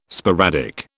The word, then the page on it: sporadic: British English